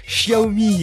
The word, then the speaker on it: Xiaomi: Autant j'ai intégré que Xiaomi, il fallait prononcer le X en ch, autant Huawei, j'ai du mal à prononcer le H comme le petit R qui va bien.